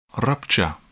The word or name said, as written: rupture